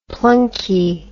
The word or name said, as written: plunkie